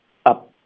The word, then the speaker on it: up: 就变成 up 这里呢挺有意思的，这就是我们今天的一个发音重点 OK，我们发 up 结尾要省略这个 p 的音，因为接下来是个辅音 w 的音，